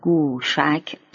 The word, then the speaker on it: گوشک: از صورت عربی شده یک کلمه گوشک جوسق برمی آید که تلفظ قدیم آن در فارسی به گاف فارسی و واو مجهول و فتح شین بوده است.